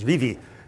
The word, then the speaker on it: Vevey: Vevey. But I went there a couple of years ago and discovered the local pronunciation is Vivi.